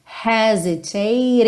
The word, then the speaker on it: hesitate: Atenção que esse H não é mudo, ele tem o som h, ok? Então, the time to hesitate is through.